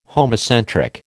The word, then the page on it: homocentric: American English